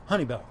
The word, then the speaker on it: Hunnibell: This is a Grand Canyon Oral History with Mark Hunibell, H-U-N-N-I-B-E-L-L. Did I pronounce that right? – Hunibell. – Hunibell, thank you.